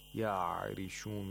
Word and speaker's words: يَعْرِشُونَ: Ya'rishun, we have ayin. We have to pronounce it from the deepest, deep in the throat, in the middle part of the throat, and we have to make sure that there's no movement, qalqala-like movement. Ya'rishun, not ya'rishun.